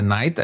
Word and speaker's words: Knight: Weil ja der, der Knight, also der, der Ritter, ja. so ausgesprochen wird wie die Nacht, ne, im Endeffekt.